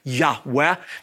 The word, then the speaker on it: Yahweh: As a result of that, the Gentile church began to refer to God as Jehovah rather than the original Yud-Heh-Vav-Heh, pronounced Abrethi, Yahweh. Now, let me say this as well. We are not totally certain, we're not 100% sure that God's sacred personal name is pronounced a breathy Yahweh because we don't have the accent markings and because for hundreds and hundreds of years, no one would say His name.